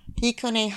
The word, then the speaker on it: Hikone: So Hikone, Hakone, and Heikone are all cities in Japan. So H-I is Hikone, Hakone is H-A, and Heikone is H-E.